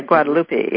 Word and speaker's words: Guadalupe: Yeah, Guadalupe, yeah.